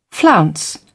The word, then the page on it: flounce: British English